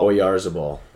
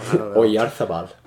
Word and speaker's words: Oyarzabal: I'm just going to say Oyarzabal. – Oyarzabal. – Yeah, I guess I was close. Yeah. Okay. I thought there might have been a Oyarzabal. – Oyarzabal.